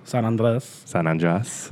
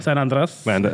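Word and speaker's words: San Andrés: San Andres. I feel like if you say it like that, people can't yell at you. Because it's Andreas and it's Andres. – Right. – San Andres.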